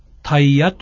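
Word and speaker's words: 大夜: 通夜はタイヤとも言います。タイヤのタイの字は様々な字を当てますが、その一つに大という字を書くことがあります。大、大きい夜と書いてタイヤと読むのです。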